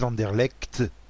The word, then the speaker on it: Anderlecht: En tout cas, en Belgique, sur les chaînes belges, ils disent Anderlecht. Donc bon, c'est qu'a priori, les Belges, ils savent comment prononcer le nom de leur propre ville.